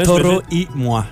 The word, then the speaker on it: Toro y Moi: Toro y moi. Ah, c'est pas super. – Toro, faut y aller avec un accent espagnol. – Toro y moi.